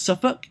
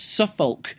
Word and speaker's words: Suffolk: you pronounce Suffolk, you don't pronounce the L in Suffolk, it's not Suffolk, and things like that.